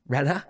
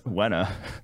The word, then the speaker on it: Renner: couldn't pronounce my R's and my L's. Really? Which is bad with the last name Renner. Um, Renner. no, it's Wenna. Michael Wenna.